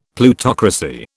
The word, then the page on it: Plutocracy: British English